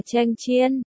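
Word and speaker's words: chen chiên (强箭): Từ cường tiễn, đọc là chen chiên.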